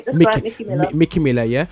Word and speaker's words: Micki Miller: That's right, Micki Miller.